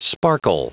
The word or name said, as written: sparkle